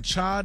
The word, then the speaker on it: chai: National Cha Day. – Okay. – Did I say that right? – Yes. Like chai latte.